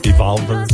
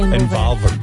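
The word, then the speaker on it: Envolver: You got Evolver. – I love how you Americans say Involver. Involver.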